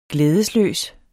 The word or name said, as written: glædesløs